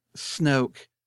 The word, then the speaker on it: Snoke: So it wouldn't surprise me at all if Snoke, pronounced it right this time.